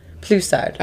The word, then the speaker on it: Poulsard: So we have Plusard, or sometimes referred to as Poulsard, which is, I think, interesting and confusing. So this is from Arbois. This is from Arbois Poupillon. And in Poupillon specifically, they call Poulsard Plusard.